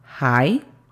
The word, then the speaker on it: hi: La pronunciación fonética se escribiría J-A-I para decir hi, y la pronunciación fonética de there sería D-E-R, con la pronunciación correcta, there.